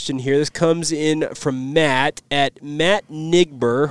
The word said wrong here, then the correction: Nyber: Hopefully I pronounced that correctly. – Nyber. – Oh, Nyber. Okay.